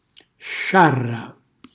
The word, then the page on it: sciarra: Calabrese